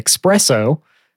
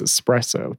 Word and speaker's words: espresso: It's like the same thing there where people say espresso. As in like the express version of coffee. That's not how it's spelled. It's espresso, two S's.